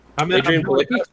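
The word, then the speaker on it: Adrianne Palicki: Palinicki. – Adrian Palicki.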